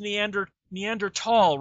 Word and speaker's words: Neanderthal: It's like, or the movie Iceman, where they keep saying Neanderthal, and you go, God damn it, you guys know it's pronounced Neanderthal, right? That even though the T and the H are butted together, they're not pronounced together.